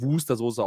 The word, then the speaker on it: Worcester: Und dann ist es ja irgendwie so, Worcester Soße, Worcester Soße. Ja, aber es ist, es ist, es wird wohl offiziell, wird es Wustersoße ausgesprochen.